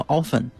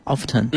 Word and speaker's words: often: 举个简单例子，你会发现，比如说我们原先学英文那个经常的这个音叫 often，对吧？ T 是不发音的，但是你会发现很多美国人大白话就叫 often。